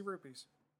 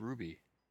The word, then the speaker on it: rupees: How do you say ruby? – Rupees. – Is that how that word is pronounced? – Yeah. – Rupee? – Yeah. – R-U-P-E-E, I assume. – I have always said ruby. – There's no B. – There's no B in it.